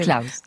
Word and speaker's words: Claus: On dit Claus, alors, parce qu'on s'est demandé, comme ça s'écrit avec un C, si c'était Claus ou Claus. – Claus Drexel. – C'est Claus, d'accord.